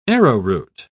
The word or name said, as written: arrowroot